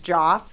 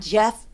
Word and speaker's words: Geoff: My husband's name is Joff, which is spelled G-E-O-F-F, Jeff. – Oh, cool. – So it's like, we do this whole thing. I'm like, call Joff. Like, and then she's like, Jeff, blah, blah, blah.